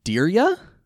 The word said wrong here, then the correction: Dierya: Yeah. – It is pronounced diarrhea. – No, why? – Diarrhea. Diarrhea. Diarrhea.